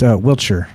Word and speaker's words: Wiltshire: Wiltshire is pronounced Wiltshire.